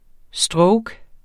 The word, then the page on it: stroke: ˈsdʁɔwg; ˈsdɹɔwg